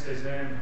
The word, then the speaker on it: Pazan: Pazan, like the French painter with a P.